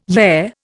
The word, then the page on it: yeah: Indian